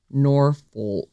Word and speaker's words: Norfolk: Oh, Norfolk. It's a difficult one to say, and because I don't want any trouble, we get that that's not how to say it. Norfolk is how that word is said.